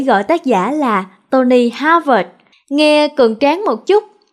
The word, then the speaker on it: Harvard: Nhiều người phát âm chữ Harvard là Harvard. Tony nghe không có hài lòng, nên đọc là Harvard nha. Vì chữ vợt nghe nó có tính chất là thể thao, kiểu như là quần vợt.